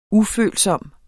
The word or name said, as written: ufølsom